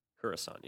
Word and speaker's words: Coruscant: It's Coruscant, yeah. You go there. Coruscant.